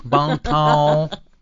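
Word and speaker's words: Bon Temps: Bon Ton.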